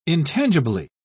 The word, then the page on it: intangibly: British English